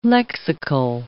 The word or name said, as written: lexical